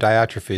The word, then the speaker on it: Diotrephes: How'd I do on Diotrephes? Is that how you pronounce it? – That's how I pronounce it.